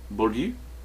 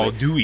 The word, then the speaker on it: Bourdieu: Bourdieu is how I pronounce it. – Bourdieu? No, you don't do the Dewey. Well. Not in French.